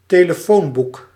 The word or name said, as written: telefoonboek